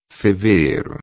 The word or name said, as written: fevereiro